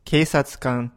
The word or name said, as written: keisatsukan